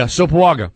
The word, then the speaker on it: Sopoaga: Schefter is tweeting that it sounds like the New England Patriots are close to trading for Eagles defensive tackle Isaac Sopoaga, I believe. I'm trying not to butcher the name. – Yeah, Sopoaga. That's exactly right.